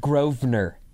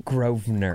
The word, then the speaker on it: Grosvenor: It's pronounced Grosvenor. – Grosvenor, okay. Were there like 5,000 of those? – Yes. – It's spelled Grosvenor. – It's pronounced Grosvenor.